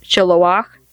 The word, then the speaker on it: Shiloach: The pool of Siloam, which in Hebrew is pronounced Shiloach, means sent.